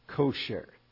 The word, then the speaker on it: kosher: Kosher, y estoy pronunciando como en hebreo, quizá en español se dice kosher, no sé, pero como termina en ere, pero les digo que en hebreo decimos kosher, ¿ok?